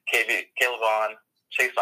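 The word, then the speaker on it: K'Lavon Chaisson: So I'm going to go with Kayvon Chaisson out of LSU. – All right. I'm congratulations on pronouncing his name correctly. I think you did, because I can't do that.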